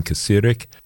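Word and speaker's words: Kacirek: It's a duo consisting of German electronic experimental drummer Sven Kasirek, that's K-A-C-I-R-E-K, Sven Kasirek, and singer-songwriter and instrument maker Olaf Rategó, R-A-T-E-G-O.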